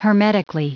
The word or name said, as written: hermetically